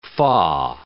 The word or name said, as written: fa